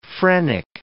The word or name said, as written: phrenic